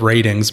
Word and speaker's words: RTINGS: It's called Ratings, but it's spelled R-T-I-N-G-S.